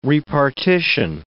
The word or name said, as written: repartition